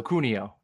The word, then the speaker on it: Cuneo: Hard C, so Cuneo.